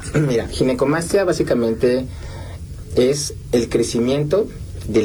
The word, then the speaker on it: Ginecomastia: Pues muchas gracias, y sí, definitivamente creo que es una, para empezar la palabra, le decía, ¿cómo se pronuncia? Ginecomastia. – Sí, exacto, es ginecomastia.